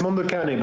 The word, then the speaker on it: Mondo Cane: Okay, and I've seen it, but been pronouncing it incorrectly the entire time. – it's Mondo Cane, but okay. For example, in French, when somebody asks me what's the name of my project, I say Mondo Cane, basically.